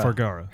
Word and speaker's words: Vergara: But just think, he was really that close to that super hot Vergaris? How do you say it? – Vergara.